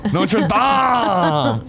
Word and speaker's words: Notre Dame: The Dame in Notre Dame is pronounced like Greg says Dom in Fast and Furious. Dom! Notre Dame!